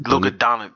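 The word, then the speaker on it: Luka Dončić: Luka Doncic.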